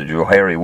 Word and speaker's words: Johari: One of the guys' first name was Joe, and the other guy's first name was Harry, so they just called it the Johari window. They spell it J-O-H-A-R-I, so it looks kind of weird, but it's interesting.